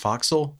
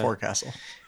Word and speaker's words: Forecastle: Did you know it's pronounced Foxel? – I did. Yes. – You didn't know that? I had a guy that was from the Navy be like, that's how you pronounce that. Not Forecastle.